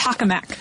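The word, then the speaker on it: tokamak: Is pronounced tachomac? – Yeah, I keep thinking taco.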